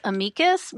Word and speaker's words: amicus: But the actual Latin pronunciation is amicus. We call the show Amicus. And just to throw one more curveball, Justice Stephen Breyer calls it amicus.